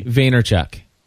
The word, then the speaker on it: Vaynerchuk: Pronounce his last name for me. – Vaynerchuk.